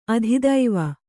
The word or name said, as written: ಆದಿದೇವ